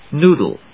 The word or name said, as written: noodle